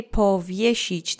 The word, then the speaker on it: powiesić: Let's say powiesić together three times. Accent is on the middle syllable. Powiesić, powiesić, powiesić. Great. Remember the S-I letter combination goes into a sh sound.